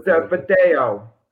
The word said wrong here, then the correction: Verdugo: How do you pronounce his Verdugo. – Name? – Verdugo.